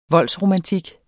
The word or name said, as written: voldsromantik